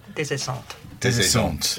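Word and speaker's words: Des Esseintes: It's about a character called Desessent. That's right, isn't it? How's my pronunciation? – Desessante. – Yeah. Thank you. Not Des, as in desert.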